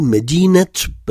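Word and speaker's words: Medinet (مدينة): Dans ce cas, il faut prononcer et. Par exemple, si je dis la ville de Paris, je dis Medinet Paris.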